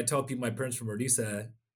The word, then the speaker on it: Orissa: To go back a little bit, my parents emigrated from a smaller state in India called Orissa is the pronunciation for it, in Eastern India.